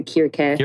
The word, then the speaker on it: Circe: Some people go with the Greek, the Kyrke. – Kyrke, yeah. That does help us Cersei.